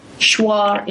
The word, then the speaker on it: Shuar: is your experience in Ecuador with the Shuar Indians. Is that how you pronounce them? – Shuar Indians.